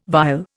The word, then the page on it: vile: Indian